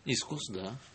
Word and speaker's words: искус: Я не знаю, где ставится ударение в этом слове. – Искус, да.